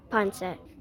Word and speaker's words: pancit: Am I saying it wrong? Thank you for correcting me. How do we say it? Ponset. Ponset? Thanks for telling us. Tell us about it.